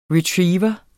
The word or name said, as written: Retriever